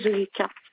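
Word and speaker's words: Zoulikha: Madame Moumi Zulika. – Comment s'écrit le nom de famille? – Écrit M-O-U-M-N-I. Z-O-U-L-I-K-H-A.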